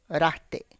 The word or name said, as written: rate